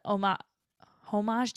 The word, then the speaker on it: homage: Cause Coney Island plays a homage. Do you pronounce the H? – Homage.